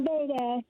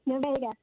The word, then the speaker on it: Nevada: Am I saying that right? – Yes. – Pardon? – Nevada. Yes, Nevada. – Great.